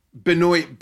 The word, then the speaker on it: Benoit: Yeah, I joke because I was a huge basketball fan back in the day, and there was a player named Benoit Benjamin is how they pronounced his name.